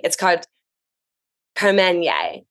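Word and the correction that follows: Carmenère: Carmenere, Carmenere, which is sort of a blend.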